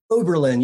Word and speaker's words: Oberlin: Did I pronounce that right? – Oberlin, yeah.